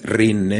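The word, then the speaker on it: Rhein: Es Rin, ¿eh? No crean que lo estoy pronunciando mal, es R-I-H-N. Es en alemán, ¿no?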